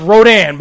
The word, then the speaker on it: Rodan: And it's pronounced Rodan, by the way.